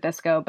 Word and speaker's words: VSCO: Yeah, so Visco, which is spelled V-S-C-O, we pronounce it Visco, but you can pronounce it however you want.